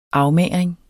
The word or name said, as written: afmagring